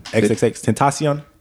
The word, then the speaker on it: XXXTentacion: XXXTentacion.